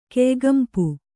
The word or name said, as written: ಕೆಯಿಗಂಪು